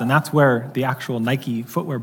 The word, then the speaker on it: Nike: There's a Greek word, Nike, transliterated the same way, N-I-K-E, Nike.